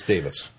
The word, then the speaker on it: Davies: It's Davis.